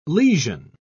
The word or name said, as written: lesion